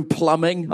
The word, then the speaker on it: plumbing: I don't know how to do plumbing. – Right. – I can barely even pronounce the word without saying the B.